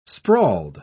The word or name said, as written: sprawled